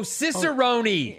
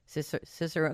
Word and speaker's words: cicerone: Is it a hard E at the end there or silent E? Cicerone, because I'm going to have to say this to him later. – Cicerone. – Oh, right. Cicerone, you know it to be.